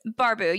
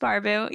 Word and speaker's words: Barbu: It's Barbu. You said it right. Yes, okay, sweet. You said it totally fine. So many people are like, is it Barbu? Barbu? I'm like, just Barbu.